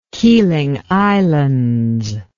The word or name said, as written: keeling islands